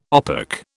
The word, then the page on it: OPEC: British English